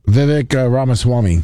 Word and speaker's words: Vivek Ramaswamy: How do you say his name? Let me. – Vivek Ramaswamy. – Yeah. Oh, there you go.